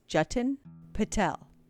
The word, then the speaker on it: Jatin Patel: It's J-A-T-I-N, and it's pronounced Jatin Patel, P-A-T-E-L, mm-hmm.